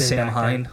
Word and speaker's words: Samhain: it's spelled S-A-M-H-A-I-N, but the pronunciation is Samhain. – Neat. – Very cool. – I just love thanks, Sam Hine.